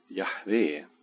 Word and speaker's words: Jahve: Och eh, någon har placerat vokalerna från Adonai mellan JHVH och fått Jehova. Och eh, från det här är då förslaget att namnet skulle vara Jehova. Men ett troligare uttal är Jahve och det är det som vi kommer att hålla oss till.